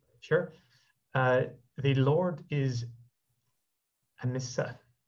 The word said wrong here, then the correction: Ahimsa: Ahimsa. The Lord is Ahimsa.